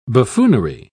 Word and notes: buffoonery: British English

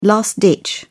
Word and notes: last ditch: British English

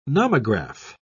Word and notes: nomograph: British English

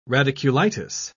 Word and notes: radiculitis: British English

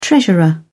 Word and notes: treasurer: British English

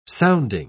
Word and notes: sounding: British English

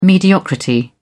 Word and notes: mediocrity: British English

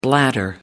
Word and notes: bladder: American English

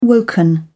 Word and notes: woken: British English

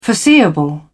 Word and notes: foreseeable: British English

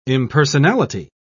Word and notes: impersonality: British English

